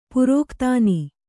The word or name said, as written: ಪ್ರಾಕ್ತನ